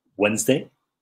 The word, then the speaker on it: Wednesday: Por ejemplo, Wednesday, no Wednesday, decíamos, ¿no? Que aquí no se pronuncia la D.